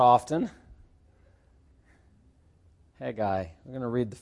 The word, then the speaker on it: Haggai: Some of you pronounce it Haggai, a little extra little syllable in there, or vowel, Haggai.